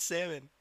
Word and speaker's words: salmon: It's salmon. – Why is it salmon? – The L is silent.